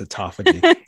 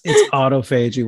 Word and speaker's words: autophagy: You don't know how to pronounce that. It's autophagy. it's autophagy. We'll talk about it in a second, but autophagy is fine as well.